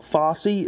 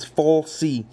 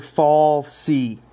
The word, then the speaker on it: Falsey: Fossey is not right, it's Falsey. That's Dr. Fall C. It's F A S, no, F A L S E Y. Fall C.